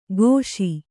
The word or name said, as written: ಘೋಷಿ